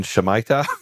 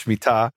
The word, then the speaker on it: Shemitah: This became very popular a few years ago in the U.S. under the mispronunciation Shemitah, but it's Shemitah, which is also called the sabbatical year.